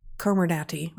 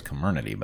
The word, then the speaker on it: Comirnaty: Furthermore, we observed significantly higher IgG4 levels after two doses of Comirnaty, which I also never know how to pronounce, the Pfizer vaccine. – I thought it was Comirnaty, but... – Comirnaty? Okay, I'll start over. I'll go with Comirnaty.